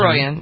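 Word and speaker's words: Troian: Is it Troyan? Is that how you pronounce it? – Troyan, yep.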